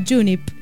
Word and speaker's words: Junip: Se llama Junip. ¿Está bien pronunciado? Sí, me dicen que sí.